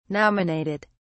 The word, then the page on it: nominated: British English